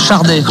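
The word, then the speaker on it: Sade: Par contre, ça se prononce Chardé, avec un R qui n'existe pas, mais on dit Chardé normalement. Et je crois que d'ailleurs, elle le dit, oui, elle le dit elle-même, Chardé.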